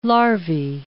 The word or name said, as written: larvae